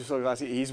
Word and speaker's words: Sokolowski: Andrew Sokolowski, he's the founder of Live Well, and he's the wellness specialist and gerontologist right here in Naples, Florida.